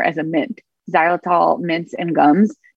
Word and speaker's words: xylitol: Um, in case like if someone wants to go- Google it, it's with an X. It's like X Y xylitol.